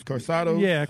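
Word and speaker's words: Karsatos: How do you say that, Juice? It's an Ohio name. – Carsados. – There you go.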